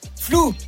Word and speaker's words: flew: Flou. – Flou. – Alors, en soi, je pense qu'elle a le bon mot, c'est la prononciation qui pose un petit problème. On dit flou.